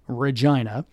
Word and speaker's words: Regina: And yes, that's how you say it, Regina.